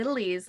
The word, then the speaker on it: littlies: them littles. It's supposed to be littlies, like uglies.